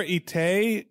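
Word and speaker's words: Étaix: Oh, his name is Pierre Itaix, E-T-A-I-X.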